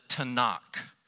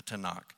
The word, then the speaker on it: Tanakh: Notice the four consonant sounds in that Hebrew word. Almost every Hebrew word consists of three consonants and vowel sounds. That's the general pattern for Hebrew vocabulary. So Tanakh has a T and an N and then a KH sort of sound.